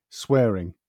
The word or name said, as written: swearing